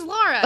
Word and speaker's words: Lara: My name is Laura. L-A-R-A.